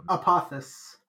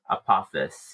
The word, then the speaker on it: Apophis: Apophis. – Apophis, yeah, is our actual villain.